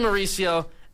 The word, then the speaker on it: Mauricio: I don't even know how to say it, just uprising of Brett Beatty, Ronnie Mauricio, there's the word, Ronnie Mauricio, and Francisco Alvarez.